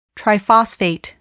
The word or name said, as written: triphosphate